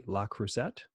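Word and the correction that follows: Le Creuset: La Crusade. Yeah. La Crusade. – There you go.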